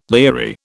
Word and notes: leery: British English

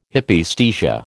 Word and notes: Hypaesthesia: American English

